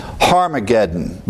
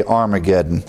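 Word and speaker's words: Harmageddon (Ἁρμαγεδών): You add an H sound to the pronunciation. Harmageddon. But normally we just drop that H and say Armageddon in our popular pronunciation.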